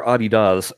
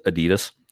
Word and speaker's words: Adidas: Somebody had told me that only in America do we pronounce it Adidas for your shoes. – We call it Adidas here. – Oh, it's Adidas. Say it again. – Adidas. – Adidas. – Adidas. – Okay. So not Adidas.